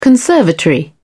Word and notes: conservatory: British English

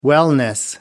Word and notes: wellness: American English

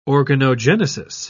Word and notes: organogenesis: British English

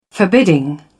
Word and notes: forbidding: British English

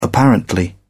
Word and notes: apparently: British English

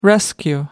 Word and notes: rescue: American English

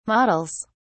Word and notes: models: British English

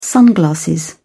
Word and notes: sunglasses: British English

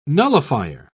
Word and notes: nullifier: British English

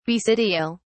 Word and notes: basidial: British English

